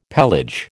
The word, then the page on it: pelage: American English